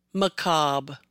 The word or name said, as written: Macabre